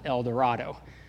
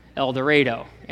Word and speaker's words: El Dorado: Now, if you speak Spanish or if you've taken Spanish, you know it's pronounced El Dorado, but that's not how we Kansans say things. It's El Dorado, and it's Arkansas, not Arkansas.